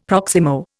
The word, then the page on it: proximo: Indian